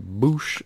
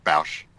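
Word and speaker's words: Boushh: I always love when people say Boosh. I just think it's funny. – Well, how do you pronounce it? – Bausch. – Yeah, Bausch. – But I've heard both ways.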